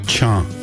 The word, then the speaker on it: cha: In Denny Willie, how do you say rain? – Cha. – Cha. – Yes. Cha means rain.